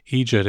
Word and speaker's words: eejit: My view is that, yes, while we have Amadáns and, and so forth, and many other words for fools, eejit is specifically a formation of idiot phoneticized the way we pronounce it here, with that, that soft G, that kind of the eejit.